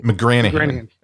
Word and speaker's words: McGranaghan: McGranaghan. Okay. – It's confusing because there's a silent G in there that some of the members of the family who came to America dropped that and some did not.